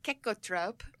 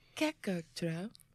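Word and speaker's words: cecotrope: It's spelled C-E-C-O, tropes. – C-E-C-O. – Yeah. – Kecko. – Kekkotrope. – I have no idea. – Kekkotrope.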